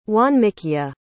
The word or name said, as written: wanmikiya